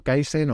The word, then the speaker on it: Kaizen: Eh, tengo este podcast que tú has mencionado, Kaizen o Kaizen, porque se escribe con Z, aunque es una palabra japonesa.